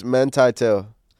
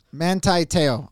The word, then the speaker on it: Manti Te'o: Okay, so say his name for it's Manti Te'o. – Manti Te'o.